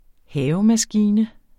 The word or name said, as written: havemaskine